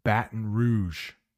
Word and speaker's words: Baton Rouge: There is a city in the United States. It's a French name. Baton Rouge is how we pronounce it.